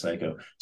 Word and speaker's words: Siko: I know, yeah, S I K O, but it's pronounced Psycho.